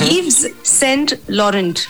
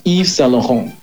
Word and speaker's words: Yves Saint Laurent: Yves Saint Laurent. – It should be Yves Saint Laurent. – Nobody can say it like that, except the French.